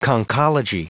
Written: conchology

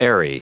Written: airy

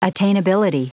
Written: attainability

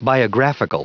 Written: biographical

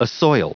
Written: assoil

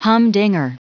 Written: humdinger

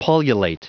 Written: pullulate

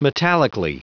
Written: metallically

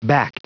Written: backed

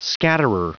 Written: scatterer

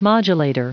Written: modulator

